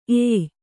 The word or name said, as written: ಏಯ್